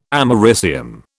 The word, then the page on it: americium: British English